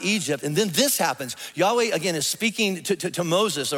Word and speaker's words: Yahweh: So in order to pronounce it, we pronounce it Yahweh.